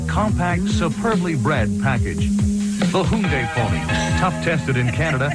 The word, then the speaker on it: Hyundai: Get immediate delivery now at any of your Lower Mainland Hyundai dealers.